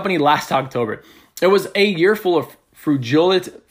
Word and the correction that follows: frugality: frugility, frugality.